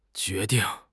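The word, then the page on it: jue2 ding4: tone 2; tone 4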